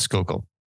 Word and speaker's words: Schuylkill: It's actually called Schuylkill. And like many of the provinces up there in Pennsylvania, they have Eastern European roots. So they add a lot of consonants where they don't need them. So it's spelled Schuylkill. It's Schuylkill.